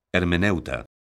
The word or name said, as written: hermeneuta